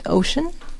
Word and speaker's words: OSHN: Yeah, so we're actually about to, well, we have just piloted something called Ocean. In Merseyside. – Okay. – So it's spelled O-S-H-N.